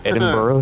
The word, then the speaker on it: Edinburgh: Edinburgh? It's like, it's like a thing at the end, Edinburgh. – Edinburgh. – But it's not borough. It's like a, it's like in between, right? Edinburgh.